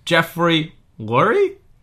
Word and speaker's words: Jeffrey Lurie: Jeffrey Lurie? – That's right. – Yeah. – You pronounced it perfectly.